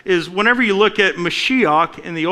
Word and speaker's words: Mashiach: And the way that you pronounce that is Mashiach. And if you don't sound like you're hawking up something, you don't say it right.